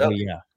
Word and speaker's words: Uvalde: Do you pronounce it Uvalde? – Yeah. – Is that how it's pronounced? – Uvalde, yeah.